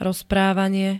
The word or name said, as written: rozprávanie